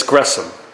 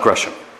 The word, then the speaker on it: Gresham: Gresham Machen was born, and it's Gresham. I know people struggle with the pronunciation of his middle name. It is Gresham. The H is silent for whatever reason. It's not Gresham. I was saying it my first year of seminary, and I got corrected.